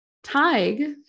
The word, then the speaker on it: Tighe: He lifted a candle in front of Teague's face. Did I say Teague? – How do you spell it? – T-I-G-H-E. – Oh, that's a toughie. – I think it's Tig. I'm gonna say Tig.